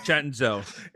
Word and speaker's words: Cenzo: Cenzo. – It's Cenzo. – C-E-N-Z-O, spelled Cenzo, pronounced Cenzo. – Or spelled Cenzo. Either way, however you wanna say it. But C-E-N-Z-O.